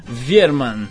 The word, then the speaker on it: Wiermann: que é Virman mesmo? – Virman. – Virman.